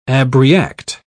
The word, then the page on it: abreact: British English